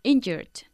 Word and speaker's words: injured: Injured. – Emphasizing the first syllable. Injured.